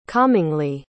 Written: calmingly

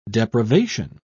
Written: depravation

